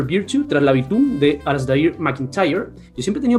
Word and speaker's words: Alasdair: En realidad, depende si lo pronuncia un americano, un británico o un escocés, pero dejémoslo en Alistair. – Alistair.